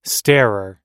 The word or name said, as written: starer